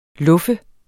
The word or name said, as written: luffe